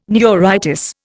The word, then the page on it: neuritis: Indian